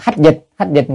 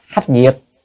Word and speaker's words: hách diệt: Chúng ta không nên đọc là hách dịch người ta hiểu lầm, cho nên phải đọc hách diệt.